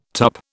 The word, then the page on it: tup: British English